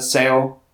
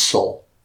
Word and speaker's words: Seoul: cut ties with her family in Seoul. – It's Seoul. – Oh, is it? – Yeah.